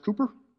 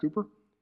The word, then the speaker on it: Cowper: It's pronounced Cowper, but it's pronounced Cooper. Excuse me, it's spelled Cowper, but it's pronounced Cooper.